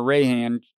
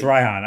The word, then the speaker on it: Reihan: But Reihan is at least either Raihan or Rayhan. No, actually, I think it's Raihan.